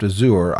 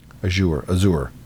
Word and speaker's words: Azure: Azure. Am I saying that right? – I think it's Azure.